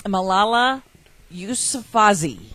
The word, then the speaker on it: Malala Yousafzai: Malala Yousafzai.